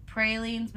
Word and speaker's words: praline: I learned how to make gumbo and etouffee and pralines, but they say that you're supposed to pronounce it praline or else you'll pretty much get judged.